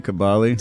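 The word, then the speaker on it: Cobolli: Am I saying his name right? Cabali? – Cabali.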